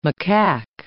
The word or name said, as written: macaque